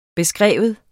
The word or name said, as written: beskrive